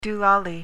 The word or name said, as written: doolally